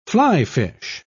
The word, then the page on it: fly-fish: British English